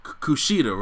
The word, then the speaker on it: Kushida: It's the Kushida, right? – Yes.